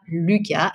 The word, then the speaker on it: Luca: Alors, vous pouvez me retrouver sur LinkedIn sous le nom donc, de Cynthia Luca, L-U-C-A. Et mon entreprise, c'est Biscuit, B-I-S-C-O-U-I.